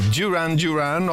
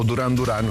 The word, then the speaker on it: Duran Duran: Tornano insieme a lavorare i britannici di Birmingham, i Duran Duran, o Duran Duran come siamo abituati noi italiani a pronunciarlo, insieme a Nile Rodgers.